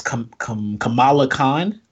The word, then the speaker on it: Kamala Khan: And just to give a little background on Ms. Marvel, her name is, and I hope I'm pronouncing this right, in the comic books her name is Kamala Khan. – Yes, sir.